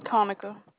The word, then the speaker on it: Conica: Conica. – Oh, Conica. Okay. Like Monica.